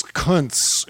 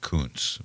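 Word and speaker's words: Kunce: And so there was this guy on Twitter, Lucas Kuntz, who is a veteran of Iraq and Afghanistan. – Yeah. – And he ended up in a debate with Ben Domenech, who said I think it's pronounced Kuntz.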